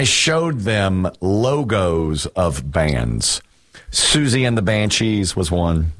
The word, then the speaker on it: Siouxsie: Susie is spelled S-I-O-U-X-S-I-E.